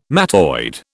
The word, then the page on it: mattoid: British English